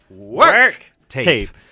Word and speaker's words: Werk: Now, we have to get the pronunciation properly. It's the Work tape. W-E-R-K.